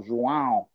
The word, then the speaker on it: João: but there is this accent that is pronounced nasally, coração, João, galegão, portão, that sound, it took me virtually weeks to get it.